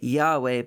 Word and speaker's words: Yahweh: Now we pronounce it Yahweh, but in truth, it's unpronounceable. What God actually said were the Hebrew consonant letters Yod, He, Vav, He. In English, it would be the letters Y H W H.